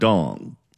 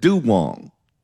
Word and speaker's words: Duong: And some people in the comment section have been asking why they're pronouncing the name Duong and not Dong. When we first started covering this story, Randy, we were not on in the Bay Area. We were on in Southern California, but we were covering this story. And the first, I think, 3 to 5 reports that we played, every single one of them, including Ashley Zavala, pronounced it Dong. And then somewhere along the line, some of them started pronouncing it Duong. – That is correct.